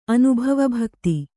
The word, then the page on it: ಅನುಭವಭಕ್ತಿ: kannada